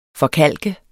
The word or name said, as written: forkalket